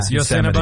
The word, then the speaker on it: Yosemite: You didn't say it right, but we know what you meant. Yeah. Yosemite.